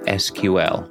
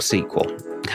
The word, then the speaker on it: SQL: On Developer Voices this week, we're exploring the ever-thorny problem of SQL, or SQL. How you pronounce it is the minor problem.